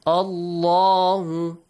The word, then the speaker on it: Allah: The lam in the word Allah will be pronounced with a heavy sound. La. So that's why we pronounce it this way. Allahu.